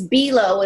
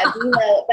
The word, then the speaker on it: Belau: Yeah, it's Belau, and we always say, look out Belau. – Oh, Doni Belau. – Yeah, Belau.